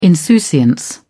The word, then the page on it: insouciance: British English